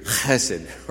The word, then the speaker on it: chesed: So you have to say, in Hebrew, chesed, right? You have to sound like you're coughing to say it correctly.